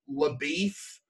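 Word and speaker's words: LaBoeuf: Like, sometimes it'd follow Maddie, sometimes it'd follow Rooster Cogburn, sometimes it would follow LaBeef, which is one of the weirder things that was kept intact between movies, that pronunciation. – LaBeouf, like, yeah.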